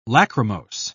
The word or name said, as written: lachrymose